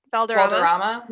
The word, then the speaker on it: Valderrama: Valderrama.